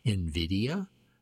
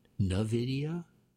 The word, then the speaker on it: NVIDIA: how to say its name. NVIDIA, not NVIDIA.